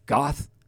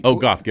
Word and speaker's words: Goff: Goff. – Goff.